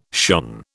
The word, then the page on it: shone: British English